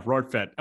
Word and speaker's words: Rortvedt: Ben Rortvedt? Yeah, Rortvedt.